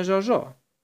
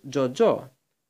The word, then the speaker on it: Τζοτζό: «Θα το φωνάζουμε Ζωζό». «Όχι», είπε ο Ζωακίμ. «Πρέπει να προφέρεις Τζοτζό».